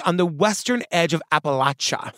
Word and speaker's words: Appalachia: Thank you for saying Appalachia. – I know, because you corrected me last time. Wait. Hear a bell for me. – They'll throw an Appalachia if you say it wrong.